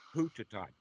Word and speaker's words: Puttatat: Puttatat, P-U-T-T-A-T-A, Puttatat. – Puttatat. – It's the same word in the Pali, it's just the Thai pronunciation. The B's and the P's are kind of mixed up. The T's and the D's are kind of mixed up. The S becomes a T. The last syllable gets dropped off, the A.